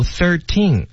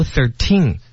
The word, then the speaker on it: thirteen: 大家注意哈，就有的时候呢我们可以读 thirteen，有的时候可以读 thirteen，就是它的重音前后都是可以的，对不对？ – 对。